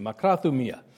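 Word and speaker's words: makrathumia (μακροθυμία): Looking up the word in the Greek dictionary, makrathomia. I'm not a Greek scholar, but that's how they say to pronounce it, makrathumia.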